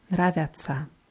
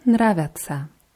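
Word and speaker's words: нравится: Vergleich mal Nravica für Singular und Nravica. Dieses Ja statt dem I hört man. – Ja. Sag du mal Nravica. – Nravica.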